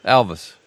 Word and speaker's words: Alvis: And you said you pronounce it Elvis, but it's spelt with an A, is it? Is it Elvis or Elvis? Elvis. – A for Apple, A-L-V-I-S.